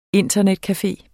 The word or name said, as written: INTERNETCAFE